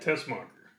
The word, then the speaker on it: Tessmacher: How do I pronounce that? – Tessmacher.